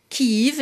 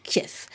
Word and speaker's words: Kyiv: Désormais, c'est sûr, lorsque vous les entendez, comme dans les éditions spéciales que nous avons encore diffusées en début de semaine, ils disent bien Kiev. Cela s'écrit K-Y-I-V, Kiev, et non pas Kiev.